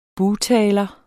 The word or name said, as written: bugtaler